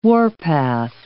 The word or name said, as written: warpath